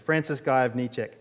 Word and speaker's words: Gajowniczek: One of the men selected, his name was Francis Gajowniczek, uh, spelt um, not the way it sounds, but that's how you pronounce it. Francis Gajowniczek.